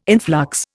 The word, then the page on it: influx: Indian